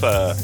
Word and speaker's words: pho: Well, faux, but it's actually pronounced pho.